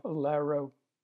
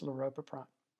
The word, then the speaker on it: laropiprant: You'll hear discussions and pronunciations of laropiprant or lorapaprant. Most of the time, it's lorapaprant.